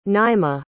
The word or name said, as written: naima